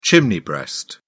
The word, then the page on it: chimney breast: British English